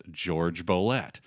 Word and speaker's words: Jorge Bolet: There's a piano player named George Bolet, but it's spelled Jorge Bolet. But he wanted his name to be pronounced, anglicized, as George Bolet.